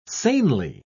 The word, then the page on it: sanely: British English